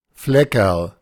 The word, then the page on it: Fleckerl: German